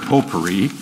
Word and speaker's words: potpourri: Obviously, that's a French word, potpourri. You don't pronounce the T.